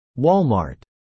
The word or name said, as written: Walmart